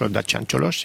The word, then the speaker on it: Dacian Cioloș: Dacian Cioloș, si vous voulez prononcer à la roumaine, et donc ayant été commissaire.